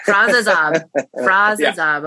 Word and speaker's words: Frass Azab: Frass Azab.